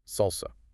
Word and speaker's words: SLSA: And the key framework here is SLSA supply chain levels for software artifacts, pronounced SALSA.